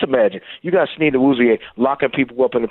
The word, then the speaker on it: Awuzie: So he has the capability, but here's what's going to make Hooker and Elijah Moore better: Snead and Awuzie. Did I pronounce the name right? – Yeah. – Okay, Awuzie. With Snead and Awuzie, those guys are going to make up.